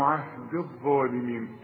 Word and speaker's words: عهدي: عهد الظالمين، الغي الياء دي بتاعت عهدي من النطق إذا وصلت، إنما تثبت إذا وقف.